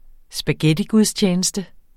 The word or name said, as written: spaghettigudstjeneste